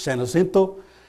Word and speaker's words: San Jacinto: Or San Jacinto is spelled with a J, but it's pronounced with an H, San Jacinto.